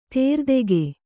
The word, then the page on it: ದಾರಿದೆಗೆ: Kannada